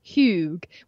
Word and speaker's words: Hygge: and that is how I came across Hygge, which is spelled H Y G G E. So it does not look like you think it's going to sound.